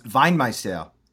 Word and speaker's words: Weinmeister: Well, so I don't know how he said it, but this is a German last name. It would be pronounced Weinmeister. Wein means wine.